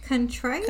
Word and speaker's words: contrite: To be crushed, be contrital. I don't know if I'm saying that right. – Contrite? – Contrile? – Contrite. – Contrite. – I think is what it says.